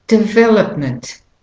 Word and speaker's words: development: The next word is development. Development. The O in this word is a schwa, U, and the main stress syllable is the vowel syllable.